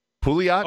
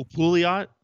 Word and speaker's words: Pouliot: Pulio. – Who? I don't. I'm, i know I'm not pronouncing that right. Derek Pouliot. Oh, Pouliot. Okay, see, I've heard the the ref the um, announcer thing that I have says Pulio.